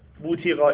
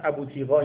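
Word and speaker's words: بوطیقا: اینا ضبطای مختلفه، من دقیق ترین ضبطش رو آوردم، مثلا ابوتیقا اینا میگن ولی اون دقیق ترین لفظش بوتیقاه. برید به انگلیسیش هم رجوع کنید میبینید همین تلفظ صحیح تره.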